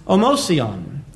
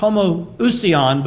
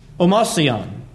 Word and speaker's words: homoousion: An old word that was found in the Greek language when Christians spoke primarily Greek is omosion. And sometimes in the modern way of pronouncing that among those who study Scripture, it's pronounced homoousion, but Greeks would say omosion.